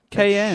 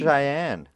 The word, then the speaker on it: Cheyenne: Cayenne. – It's Cheyenne.